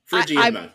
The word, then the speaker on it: Phrygian Monk: Phrygian Monk, yes, that is how you pronounce it.